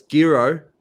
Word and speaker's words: Giro: But Craig Willis, you can't pronounce Giro. It's Giro, and the Vuelta, I think he stumbled over as well.